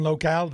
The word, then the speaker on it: Locale: is photographer and owner of Once Club in Somerville, a club that is all the rage, and owner of Cuisine and Locale. Did I say it right? – You totally did.